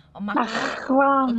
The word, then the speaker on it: macaron: So you started Sweet Mac Shop, and it's macarons. Did I say it properly? – It's kind of like a real phlegmy. – Macaron. I got a little more French. Macaron. Okay. Macaron. Yeah. You got it.